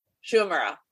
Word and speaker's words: Shu Uemura: Not Shumera? – Shu Uemura.